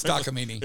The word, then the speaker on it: Takamine: So I ended up buying just kind of a little Takamine. I never know how to pronounce that correctly. – It's Takamine. – Is it Takamine? – Yep, it is. You had it. You got it.